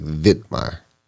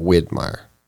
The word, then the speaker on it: Widmaier: So the last name, though, what got me was spelled with a W, but it's almost said with a V, like Widmaier. – Yeah. – Instead of Widmaier.